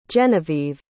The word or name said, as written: genevieve